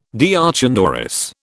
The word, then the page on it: deoch an doris: British English